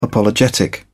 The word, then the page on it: apologetic: British English